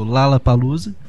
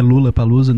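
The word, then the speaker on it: Lollapalooza: Ontem, dia 1 de outubro, tivemos um anúncio oficial do Lala Palusa. Não é Lula Palusa, nem Lola Palusa, a pronúncia correta é Lala, mas como a gente está no Brasil, a gente aceita falar Lola.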